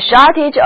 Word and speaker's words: shortage: A shortage of water， a shortage of water 就是指缺水的意思。字母 a 在这里要读作短的 e， shortage， shortage。